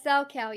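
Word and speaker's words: Salchow: And what is a sow cow jump? Did I say that right? – Yes, sow cow, yes.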